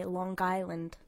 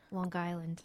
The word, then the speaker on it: Long Island: Well, we we learned a really good one that we can't say Long Island, which is how we were saying you have to say Long Island. Yeah, the G. – The G is, is essential. pronounced. – It's Long Island.